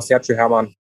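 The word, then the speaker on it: Sergio Herman: Und hilf mir mal bitte bei der Aussprache. Sergio Hermann, ist das richtig? – Ja, genau, Sergio Hermann.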